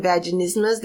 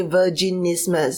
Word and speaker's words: vaginismus: So a lot of times they can't pronounce the word vaginismus. So they will say instead of saying vaginismus, they will say virginismus. So it's like it's a condition that happens to virgins, which is not true.